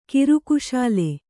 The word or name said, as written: ಕಿರುಕುಶಾಲೆ